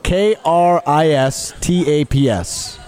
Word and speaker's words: Kristaps: Okay, let's spell it for you. K R I S T A P S. – Kristaps Yep.